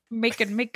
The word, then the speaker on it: Macon: Is it Macon? – Yeah, I can't remember how to say it, so I was making you say it's like bacon. – Macon.